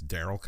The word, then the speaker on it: Darryl: That's Daryl Comedy. My name is spelled D-A-R-R-Y-L.